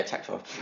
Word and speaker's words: Taktarov: Yeah, Taktarov.